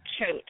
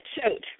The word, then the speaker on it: Choate: It's kind of a weird one. It's Choate. – Choke? – Yeah, Choate. Rhymes with goat.